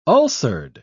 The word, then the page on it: ulcered: British English